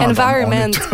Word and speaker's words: environment: On est tout environment. – Oui, bravo. C'est parce que l'accent tonique est sur la première syllabe en anglais. Hein. C'est ça la difficulté, alors qu'en français, c'est sur la dernière, alors que je parle du temps.